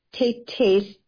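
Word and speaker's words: Taytay's: And he is sharing with us some stories from a collection of the book titled Tate Tate's Tales. So I'm saying it the way you see the title. It's spelled T-A-Y-T-A-Y apostrophe S, Tales.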